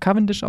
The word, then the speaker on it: Cavendish: Aber er heißt, wird tatsächlich Mark Cavendish ausgesprochen.